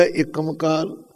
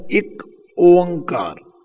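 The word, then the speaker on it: ੴ: इहदा उच्चारण भी आम लोग गलत करदे, कोई पढ़दा एकमकार, कोई पढ़दा एकमकार। इहदा उच्चारण करना है एक ओंकार, एक वखरा कहना है, ओंकार वखरा कहना है।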